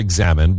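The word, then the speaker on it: Examen: I think Americans call it examine, but it rhymes with amen.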